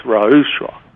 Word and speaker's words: ruach: The word for spirit is Ra'ush, and it's spelled R-U-A-C-H.